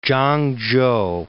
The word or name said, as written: Chang-chou